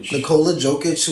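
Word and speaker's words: Nikola Jokić: Nikola Jokic,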